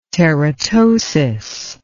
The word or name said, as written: teratosis